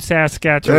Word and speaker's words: Saskatchewan: is that how you say it, man? Saskatoon, Saskatchewan. – There you go.